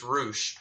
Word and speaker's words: Rush: Which is Rush, it's spelled, but it's Roosh.